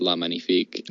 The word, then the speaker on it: La Magnifique: and then La Magnifique, which I'm probably pronouncing wrong, from Crimson Coast, which is a French five master. So. – Yeah, I think I had a copy of La Magnifique, but I think it's pronounced La Magnifique.